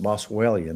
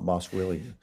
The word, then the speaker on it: Boswellia: Yeah, we pronounce it Boswellia, not Boswellia.